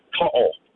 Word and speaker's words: To'o: His name is Brian To'o. – No, well, listen, You said Teo. – Hold on. – He's one of the best wingers in the game, and you can't even get his name right.